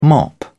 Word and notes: mop: British English

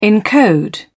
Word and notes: encode: British English

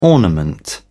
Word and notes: ornament: British English

